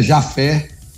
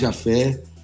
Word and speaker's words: Jafé: É, o, a pronúncia está certa, é Jafé, isso Jafé. mesmo, Carlos Jafé.